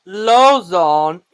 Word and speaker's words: Lauzon: Pronounced Lauzon, Quebec native, won 2017 World Junior Silver in 2017.